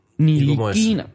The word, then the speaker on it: Ntilikina: PCL8821 gracias por comentarnos, nos dejó en una respuesta al tweet del podcast, nos dijo: parece que tiene origen ruandés. El idioma de Ruanda se llama Kinyarwanda. De ahí vendrá la pronunciación. Frank Ntilikina, y pasa una captura de cómo se pronuncia. Ntilikina.